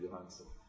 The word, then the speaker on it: Johansson: Yeah, so it's like Scarlett Johansson, the correct pronunciation, Johansson.